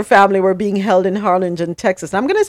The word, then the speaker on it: Harlingen: What, Harlingen? – Harlingen, Harlingen.